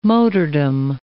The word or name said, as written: motordom